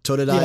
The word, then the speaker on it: Totodile: Cyndaquil, uh, Eevee, Machop, Meowth, Mudkip, Pikachu, Psyduck, Skitty, Squirtle, Torchic, uh, is it Totodile? Totodile. – Yes.